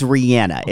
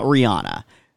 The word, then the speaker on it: Rihanna: Her name is Rihanna. It's not Rihanna. She says Rihanna, so that's how it's pronounced.